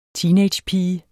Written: TEENAGEPIGER

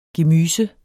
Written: gemyser

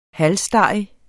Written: halsstarrig

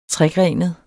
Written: tregrenet

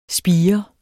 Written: spire

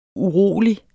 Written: urolig

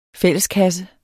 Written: fælleskasse

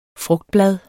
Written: frugtblad

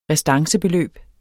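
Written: RESTANCEBELØB

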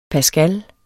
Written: pascal

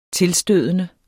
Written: tilstødende

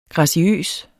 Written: graciøs

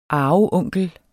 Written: arveonkel

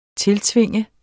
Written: tiltvinge

